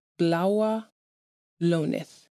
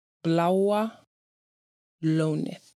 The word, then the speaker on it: Bláa Lónið: The Icelandic word of the episode is how you say the Blue Lagoon in Icelandic. And that is Bláa Lónið. Bláa Lónið.